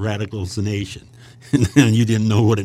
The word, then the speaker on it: sanation: But radical sanation is S-A-N-A-T-I-O-N, radical sanation.